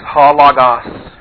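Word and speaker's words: Ha Logos (ὁ λόγος): The definite article Ha. It's just an omicron and a short O. Ah sound, but it does have the rough breather, so it's H-O, Ha. And then Logos. Lambda, omicron, gamma, omicron, sigma. L-O-G-O-S. The accent is on the first omicron. Ha Logos.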